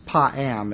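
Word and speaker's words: pa'am (פעם): The Hebrew word is pa'am. It's spelled P-A-A-M.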